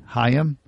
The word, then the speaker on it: Hyam: How you pronounce H-Y-A-M? – Hyam? – Hyam. Hyam Himes. H-Y-M-A-S.